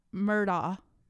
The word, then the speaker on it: Murdaugh: Let's get the pronunciation right, first of all. It's Alec Murda that's how everyone says his name, but it's spelled A L E X.